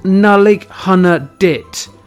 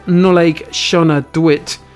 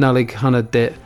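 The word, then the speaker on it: Nollaig Shona Duit: It's pronounced Nullig Hunna Dit. Not whatever Christmas alien dialect you invented. Honestly, I think points should be deducted for 1. That brutal pronunciation of Nullig Shona Dwit.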